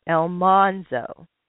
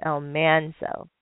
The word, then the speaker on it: Almanzo: And on the TV show, the NBC series, they always uh, pronounce it Elmonzo instead of Elmanzo.